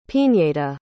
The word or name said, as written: piñeda